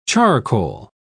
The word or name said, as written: charcoal